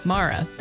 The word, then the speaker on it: Mara: Mara. – How do you spell it? – M-A-R-A.